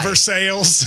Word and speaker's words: Versailles: It's like there's a town in Kentucky, Aaron, V-E-R-S-A-I-L-L-E-S. – Yes. – And we call it Versailles.